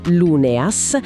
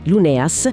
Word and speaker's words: Luneas: È una storia scritta da Virginia Luneas, attenzione alla pronuncia Luneas, e pubblicata da Casa Luneas, anche qui Luneas, nel loro progetto Scritture senza tetto.